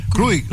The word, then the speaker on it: Juric: Iván, aquí no nos falta Víctor para la pronunciación exacta, porque es J-U-R-I-C. No sé si es Juri o Krurik, ¿cómo se dice? – Krurik.